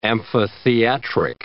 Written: amphitheatric

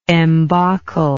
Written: embacle

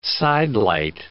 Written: sidelight

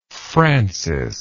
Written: frances

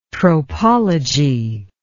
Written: tropology

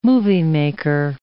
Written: moviemaker

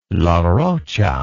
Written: Larrocha

